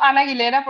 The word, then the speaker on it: Ana Aguilera: Uso Ana Aguilera porque se pronuncia muy bien pegado todo junto.